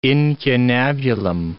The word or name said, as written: incunabulum